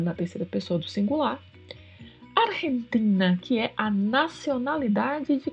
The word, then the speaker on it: Argentina: O G seguido do E é pronunciado com um som que vem da garganta. Argentina, Argentina. E o TI, assim como o DI, não é pronunciado como TI, e sim como TI. Portanto, vamos pronunciar sempre Argentina.